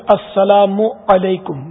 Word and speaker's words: السلام علیکم: یہی حال کوئی بولتا سلام علیکم, کوئی کہتا مالیکم سلام, کوئی پیچھے نہیں ہٹتا. تو اصل درست تلفظ السلام علیکم میم پر پیش ہے,